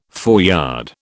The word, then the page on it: foreyard: British English